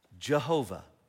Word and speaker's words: Jehovah: Which is similar to, it leads to, like a lot of German scholarship, Y's become J's, and guess what it becomes? Jehovah. It's where we get the name Jehovah. Jehovah is kind of the combination of the consonants and vowels of this word, and it becomes Jehovah.